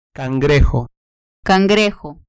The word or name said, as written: cangrejo